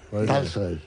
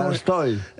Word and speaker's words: Tolstoy: Tolstoy. – Las palabras que se escriben en O en ruso, pero van acentuadas, se pronuncian casi A.